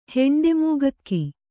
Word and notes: ಹೆಂಡೆಮೂಗಕ್ಕಿ: heṇḍe mūgakki

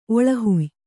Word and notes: ಒಳಹುಯ್: Kannada; oḷahuy

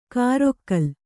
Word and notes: ಕಾರೊಕ್ಕಲ್: kārokkal

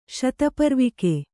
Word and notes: ಶತಪರ್ವಿಕೆ: śataparvike

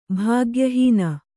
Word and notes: ಭಾಗ್ಯಹೀನ: Kannada

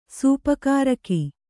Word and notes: ಸೂಪಕಾರಕಿ: Kannada

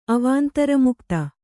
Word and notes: ಅವಾಂತರಮುಕ್ತ: Kannada

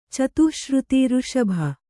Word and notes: ಋಷಭ: kannada

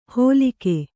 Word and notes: ಹೊಲಿಗೆ: holi'ge

